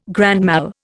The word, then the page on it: grand mal: Indian